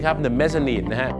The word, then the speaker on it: mezzanine: the mezzanine ครับผมนั่นแหละฮะ M E double Z A N I N E ไม่ใช่ mezzanine นะครับ mezzanine เหตุผลที่เขาต้องออกเสียงว่า nine เนี่ยเพราะถ้าไปบอก mezzanine เดี๋ยวมันนึกว่าเป็นชั้น 9 เดี๋ยวจะทับกันได้